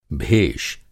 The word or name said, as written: भेष